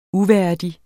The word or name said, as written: uværdig